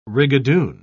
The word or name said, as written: rigadoon